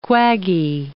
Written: quaggy

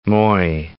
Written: Moi

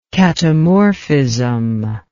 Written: katamorphism

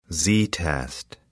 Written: z-test

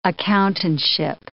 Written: accountantship

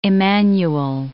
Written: Immanuel

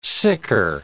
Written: sicker

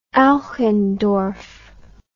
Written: Eichendorff

